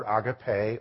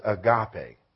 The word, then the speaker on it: agape: The highest form of love is the word agape, or more commonly pronounced agape.